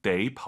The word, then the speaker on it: 得: 데이 올라가면 안 됩니다. 뒤에가 일성이잖아요. 반삼성이죠. 워먼 데이 추먼.